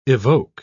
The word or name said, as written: evoke